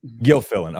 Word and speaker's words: Gilfillan: Gilfillan.